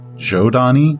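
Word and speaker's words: Zhodani: And it butts up against another race of humanity, called the spelled Zodani. I've heard it's pronounced Jodani, like with a J, because long, long, long ago, the ancients actually seeded many worlds with humans, and they all evolved very differently.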